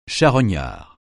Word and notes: charognard: francais

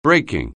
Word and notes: breaking: anglais

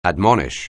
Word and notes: admonish: anglais

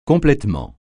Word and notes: complètement: francais

